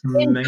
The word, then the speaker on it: Schmengie: It's spelled S-C-H-M-E-N-G-I-E. It has a C in it. – And it's Schmangy.